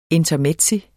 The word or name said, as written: INTERMEZZI